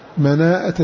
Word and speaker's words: مناءة: قال رحمه الله مناءة للمكي تلفظ بها هو، جاء بهمزة بعد ألف، مناءة للمكي زيد الهمزة.